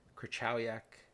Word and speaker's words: Krychowiak: And I think the one that really sticks out for me in midfield is Krychowiak.